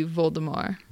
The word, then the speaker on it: Voldemort: But in French, it would be Voldemort. – Right, which was her intention, but before she could let everyone know, hey, the T is silent.